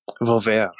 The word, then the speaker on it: Volver: Is Volver by Pedro. – That's how it's pronounced? – I don't know. It seems right. – I think you can hit the R a little harder. Volver. – Volver.